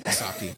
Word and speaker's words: Sokka: And the other thing you mentioned, how did you say it? Sokka? – Sokka, yeah.